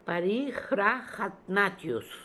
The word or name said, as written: Parijrahatnatioose